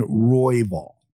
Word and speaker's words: Royval: So, Ken Flo, we're just looking for that first syllable to be emphasized. That's all we're really looking for today, right? Royval instead of Royval, right? – I like it.